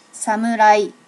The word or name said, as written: samurai